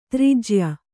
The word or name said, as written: ದುರ್ಜೇಯ